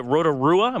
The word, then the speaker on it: Rotorua: She won a 100-mile race in, is it Rotorua? Am I saying that right? – Yep.